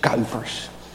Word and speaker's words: Kuipers: I would also like to thank Reverend Pastor Kuipers. Maybe you pronounce it different here, but in Dutch it is Kuipers.